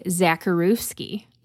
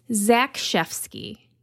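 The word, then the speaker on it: Zakrzewski: It is spelled Z-A-K-R-Z-E-W-S-K-I. I was definitely going to say Zakharovsky. I was. That's what I was gonna say. And it's Zekszewski.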